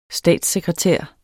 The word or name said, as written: statssekretær